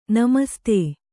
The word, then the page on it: ನಮಸ್ತೇ: namastē